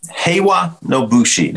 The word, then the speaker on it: Heiwa No Bushi: So Heiwa No Bushi, did I say it right? You said it correctly.